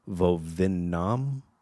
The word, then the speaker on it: Vovinam: It has its roots in Shotokan karate and blends aspects of Aikido, Judo, Wing Chun, Tai Chi, Vovinam, V-O-V-I-N-A-M, and boxing, along with training in traditional martial arts weapons.